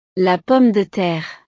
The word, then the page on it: la pomme de terre: frances